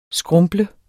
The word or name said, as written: skrumples